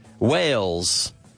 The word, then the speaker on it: Wales: Okay, so Wales. The pronunciation is the same as Ballenas. Could be Wales. Okay, but it's written differently, but the pronunciation is exactly the same.